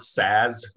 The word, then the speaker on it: SaaS: Yeah, I always just call it SaaS, like just say that it's because there's two A's there in a row, but I've heard it pronounced a few different ways.